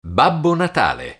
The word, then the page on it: babbo natale: Italian